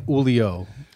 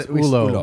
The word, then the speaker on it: Ulo: Because we were saying Ulio, but it's Ulo.